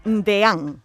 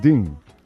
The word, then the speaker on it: deán: Creo que ha habido una periodista en algún sitio, o periodista, que ha dicho que lo ha pronunciado como DIN.